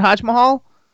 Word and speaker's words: HodgMahal: Is that how you pronounce it, Hodge Mahal? – It's like Taj Mahal, but Hodge Mahal.